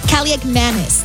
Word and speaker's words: Kaliakmanis: Washington losing quarterback Marcus Mariota to a knee injury, being replaced, yes, by Ethan Kaliakmanis. That is correct. That is how you say it.